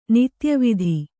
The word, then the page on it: ನಿತ್ಯವಿಧಿ: nitya vidhi